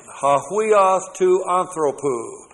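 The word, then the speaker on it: hahuios to anthropou (ὁ υἱὸς τοῦ ἀνθρώπου): For the Son of Man, hahuios to anthropou. All right, the Son of Man. Let me show you what it looks like in the hahuios to anthropou. That's H-O-H-U-I-O-S-T-O-U. A-N-T-H-R, long O-P-O-U. Hahuios to anthropou.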